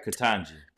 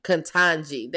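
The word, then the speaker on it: Ketanji: Katanji. – Katanji.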